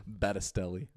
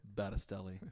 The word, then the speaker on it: Battistelli: Battistelli. – Battistelli.